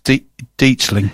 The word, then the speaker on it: Detling: No, but it's not pronounced like that. It's pronounced Detling.